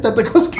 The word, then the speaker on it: Tartakovsky: Gen Tartakovsky. Tartakovsky. Ah, Así lo pronuncian en Moscú. Exacto. – Muy bien. no, en, el gringo. Gendy Tartakovsky. – En gringo. – Así lo pronuncian en ¿Por gringo. Porque en ruso sería Gendy Tartakovsky.